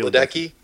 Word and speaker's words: Ledecky: So you got, like, a swimmer like Katie Ledecky, or however you say it. That's how you say it, right? – Katie Ledecky.